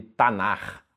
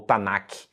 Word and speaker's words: Tanakh: Então, por exemplo, você tem um acróstico que é chamado de Tanar ou Tanakh, dependendo de como você pronuncia essa última letra.